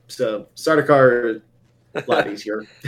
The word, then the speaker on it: Sardaukar: Sardaukar.